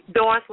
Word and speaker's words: Doris: Doris, like in Doris Day.